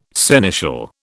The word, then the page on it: seneschal: British English